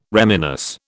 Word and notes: reminisce: British English

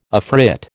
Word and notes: afrit: American English

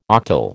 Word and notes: octal: American English

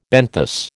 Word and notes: benthos: American English

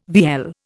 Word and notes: vielle: Indian

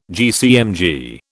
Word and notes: GCMG: British English

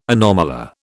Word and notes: anomalure: British English

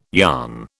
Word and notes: yarn: British English

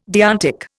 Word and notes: deontic: Indian

